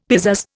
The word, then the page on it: pizazz: Indian